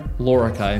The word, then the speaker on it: Lorica: And I have the great privilege today of having a conversation with Ben Lorica.